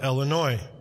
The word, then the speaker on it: Illinois: In fourth grade, we had spent the entire year studying our great state of Illinois. Yes, you heard that right. The S most assuredly is silent. It always has been, it always will be, but I digress.